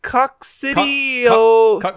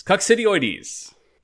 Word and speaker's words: Coccidioides: It's coccidioides. Coccidioides, yes. – Coccidioides.